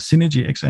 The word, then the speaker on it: Synergy XR: Jo, øh, hele vores team øh, her i, øh, ja, vi, vi, vi udtaler altså, engelsk, så Synergy XR.